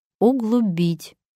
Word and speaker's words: углубить: Сейчас можно уже точно позволить себе заглянуть в словарь и узнать, что у этих слов совершенно другое ударение. Правильное ударение – облегчить, углубить.